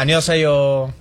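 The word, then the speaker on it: annyeonghaseyo (안녕하세요): Ay, ¿y cómo se dice? – Hola, annyeonghaseyo. Pero sí, cantadito. Annyeonghaseyo. – Como cantadito, ¿no? – Ajá, cantadito.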